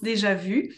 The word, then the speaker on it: déjà vu: So she wants to know more about déjà vu, because it's a French word. It's technically pronounced déjà vu.